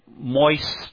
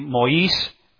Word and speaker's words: Maurice: Now, most of us English people, we'd probably say Moise Amiraud. But it's supposed to be Maurice Amiraud.